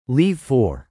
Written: leave for

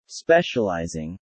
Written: specializing

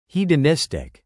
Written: hedonistic

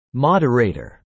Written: moderator

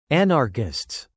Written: anarchists

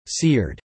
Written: seared

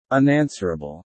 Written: unanswerable

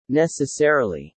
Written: necessarily

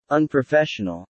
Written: unprofessional

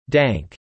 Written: dank

